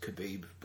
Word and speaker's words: kebab: Kebab. It's pronounced kebab, bro.